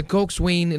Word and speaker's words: coxswain: Ang pagbasa po ng cox ay coxswain, pero pag pronounce po nito ay Coxswain.